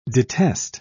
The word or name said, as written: detest